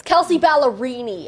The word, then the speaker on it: Kelsey Ballerini: But like for real, it's Kelsey Ballerini.